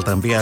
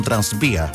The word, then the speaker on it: tranvía: Sí, el tranvía de antaño, que muchos mayores todavía pronuncian como transvía.